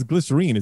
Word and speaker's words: glycerine: I know it's a weird question, but it was one thing when I heard that growing up, I was like, what the fuck is glycerine? Isn't it glycerin? And then I found out, you know, there was no internet back then, really, not much, but it was just the UK pronunciation.